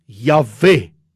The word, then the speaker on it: Jahwe: So die uitspreek van die Griekse tetragrammaton, ek is wat ek is, IHWH, as Jahwe, die I word uitgespreek as J, soos in Jasper. Die W word uitgespreek as W, soos in We. Die klemtoon in die uitspraak lê in die laaste deel van die woord E.